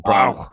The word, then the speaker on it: Brau: And I believe I pronounced Brow right, B-R-A-U. – Yeah, I'm going to go with Brow, and we're going to go German with it and call it DC Brow.